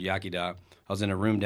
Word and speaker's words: Iechyd da: So, you know, Yakida, it's spelled really awkward. Like it starts with an I and, you know, a bunch of different language rules going on in the Welsh language.